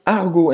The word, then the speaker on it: argot: É, é verdade que a gente esqueceu de falar de argot. Então, gíria em francês é argot. Argot. Argo, Você não vai pronunciar o T no final.